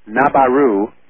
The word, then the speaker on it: Nibiru: and I'm referring to Nibiru. Nabaru is how Morne would pronounce it, that Sitchin talks about.